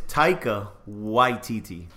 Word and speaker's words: Taika Waititi: His name is Taika Waititi.